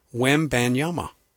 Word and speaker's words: Wembanyama: We had a pronunciation today for Victor, because it is all over the place. I just said, make it simple. It's Wembanyama. Easy.